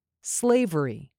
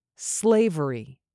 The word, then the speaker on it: slavery: Slavery. There are three syllables in this word, but the last two are said more quickly. Stress the first syllable. Slavery.